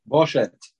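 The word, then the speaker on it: Boshet (בושת): If you put a vav there, bet, vav, shin, tav, how do you say it? – Boshet.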